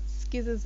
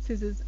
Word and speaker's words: scissors: It's not skizzers and skyans, it's scissors and science.